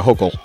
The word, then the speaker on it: Hochul: Listen, I want to plead to our new governor in the state of New York, Governor Hochul. Which rhymes with vocal, as she so eloquently put it.